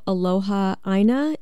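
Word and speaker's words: aloha aina (aloha ʻāina): You describe it in terms of aloha aina, is that right? Love of the land, am I pronouncing that correctly? – Yep, that's right.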